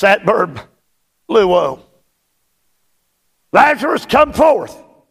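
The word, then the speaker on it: luo (λύω): It is the Greek verb luo. It is spelled L-U-O.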